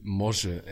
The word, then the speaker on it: Morze: Und ich bin Morze, M-O-R-Z-E geschrieben. – Wie? – M-O-R-Z-E geschrieben und ausgesprochen Morze.